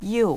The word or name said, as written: you